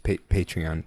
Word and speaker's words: Patreon: We are also setting up a Patron. – Are you just going to say this all the time? – It's pronounced Patreon.